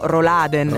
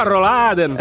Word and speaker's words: Rolladen: noi comunemente pronunciamo Roladen. – Roladen. – Ma lo sapevate che Roladen si scrive con tre L? – Ma va. – Sì, quindi non solo non è una sola come piace dire a noi, ma sono tre. – Ma no, Rolladen.